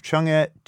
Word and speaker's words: Chang'e: And also the Japanese have a spacecraft, Chang'e 2 is how you pronounce that.